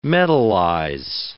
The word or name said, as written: metalize